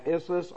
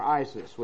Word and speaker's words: Issus: And the next major battle, in fact, the decisive battle, is going to be fought over here at, pronounced two different ways, I call the library, and Issus or Isis, whichever way scholars seem to pronounce it both ways.